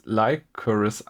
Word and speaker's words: Lycoris: Und äh, eigentlich spricht man es Lycoris aus und das ist diese, ähm, diese Spinnenlilie, diese rote ähm, Blume, die halt oft in Animes zu sehen ist.